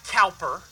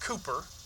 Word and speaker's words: Cowper: Now, it looks like Cowper, but it's pronounced Cooper.